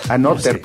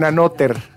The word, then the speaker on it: Anotr: Es A-N-O-T-R. No sé cómo pronunciarlo. – Entonces Anotr. – Anotr, pues sí.